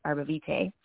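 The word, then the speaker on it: Arborvitae: It's called Arbavita. And this one is actually exclusive to doTERRA. And what I love about this essential oil is it's the Arbavita trees in Canada also can be pronounced Arbavite.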